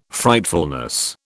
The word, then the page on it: Frightfulness: British English